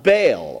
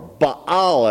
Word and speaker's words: Baal: and the false prophets called on the false god of Baal, or Baal as it's actually pronounced, and they were condemned.